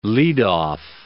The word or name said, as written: leadoff